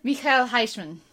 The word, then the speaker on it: Michiel Huisman: Michiel Huisman.